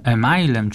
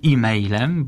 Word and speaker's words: e-mail: Z tak zwanym e-mailem czy e-mailem, bo różnie jest to wymawiane, ten angielski skrót piszemy w postaci oryginalnej, czyli e, kreseczka, mail i czytamy e-mail i to jest wymowa zgodna z wymową angielską, albo też czytamy doliterowo e-mail, czyli bardziej tak po polsku.